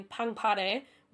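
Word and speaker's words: Pangpare (팡파레): 2016 with the song Fanfare, or if you're going to pronounce it in Korean, Pangpare, which is wildly different, but that's okay.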